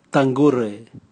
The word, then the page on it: tangure: Raga